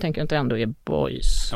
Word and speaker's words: boys: Men jag är glad, glad också att du säger boys och inte böjs, för vi har diskuterat det där uttalet, jag och lite olika besserwisser-människor på Sveriges Radio.